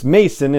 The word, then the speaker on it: Maison: and I've pronounced his name, his last name, as Maison or Maison in the past because it's M-A-I-S-O-N, and in my head the S is more like a Z. But I did ask him on Twitter, and he was very kind about it and said it's Mason, it's pronounced like Mason Jar. So Jordan Mason is actually how his name is pronounced.